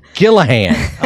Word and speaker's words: Gillihan: Did I say it right? – No. – How do you say it? – Gillihan. – Gillihan.